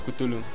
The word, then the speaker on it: Cthulhu: La llamada de Cultu, no sé cómo se dice, nunca supe. Ah, Cthulhu es.